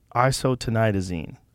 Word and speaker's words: isotretinoin: How do you say that? Try to say that for me. – Isotretinoin.